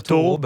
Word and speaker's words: Thoreau: Thoreau, j'ai jamais réussi à le prononcer, mais on a le droit de dire Thoreau,